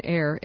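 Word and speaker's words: Ayre: Dr. Stephen Ayer, I keep wanting to pronounce it differently, it's A-Y-R-E, Stephen Ayer, M.D., has spent 27 years single-handedly researching, publishing, and speaking on the science of insulin potentiation therapy.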